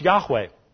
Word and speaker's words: Yahweh: Yod-Heh-Wow-Heh in Hebrew, the tetragrammaton, four letters, Yod-Heh-Wow-Heh, pronounced Yahweh.